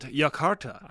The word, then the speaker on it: Jakarta: I was like, it's soft J, Josh. – And I even looked it up. And I was like, I know I've heard Jakarta. – The locals call it Jakarta.